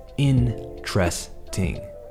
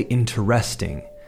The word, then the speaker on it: interesting: What we need to do is ignore that first E pretend it's not there. What you want to say is interesting. The key is to emphasize that first syllable como si fuese esdrújula, and then say tresting.